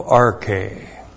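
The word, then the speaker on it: Arche (ἀρχή): And then we have apa, preposition apa, plus the genitive singular of arche. Apa plus the genitive. That's A-P-O. Apa plus the genitive is from. And then we have arche, which looks like this. A-R-C-H long E. Arche.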